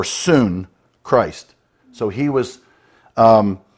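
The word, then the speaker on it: soon (σύν): So one Greek word, which is pronounced soon,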